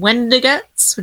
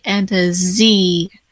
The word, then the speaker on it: Wendiguts: Yay, they can follow me on Twitter, Facebook, or Instagram under the username Wendiguts, which is spelled like a Wendigo. So, Wendiguts.